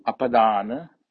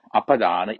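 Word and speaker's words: apadāna: Apadana sobhini, I'll explain it later. Panya, apadana, APADA, long A, NA, apadana. Next word is sobhini, SOBHINI, SOBHINI, sobhini.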